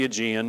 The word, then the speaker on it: Aegean: The waters of the Aegean, you can see up there on the map, that's how you pronounce that weirdo word, the Aegean Sea.